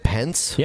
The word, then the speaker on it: Pence: Pence? – Yep, Pence.